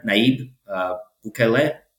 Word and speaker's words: Nayib Bukele: But speaking of El Salvador, one guy who I'm really pulling for is the president there, Nayib Bukele.